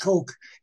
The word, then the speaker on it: Koch: Coke. It is pronounced. I found out Coke, even though it's KOCH.